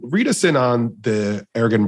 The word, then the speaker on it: Ergon: He somehow, some way, trying to appeal, I guess, to more markets, gets involved with the Ergon brand. Am I saying that correctly? – Ergon. Yeah. Either way.